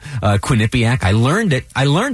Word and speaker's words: Quinnipiac: Doug and I were there together, and we over breakfast went back and forth for a few minutes quizzing each other, making sure we could correctly pronounce Quinnipiac.